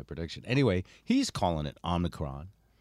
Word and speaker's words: Omicron: And there's two correct pronunciations. The British pronunciation is Omicron, and the Americanized pronunciation is Omicron.